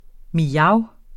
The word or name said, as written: mjav